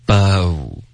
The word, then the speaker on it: bow: 第一个字是 B-O-W OK 它的发音是 bow 第二个字字是就是碗对吗 B-O-W-L bowl OK 所以第一个和第二个一起说 OK bow bowl OK 第二个字第三个字是烧对吗 B-U-R-N